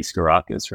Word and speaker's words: Sgourakis: I'm Henry Sgourakis from SGKS Architects from Melbourne, as you said.